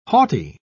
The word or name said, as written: haughty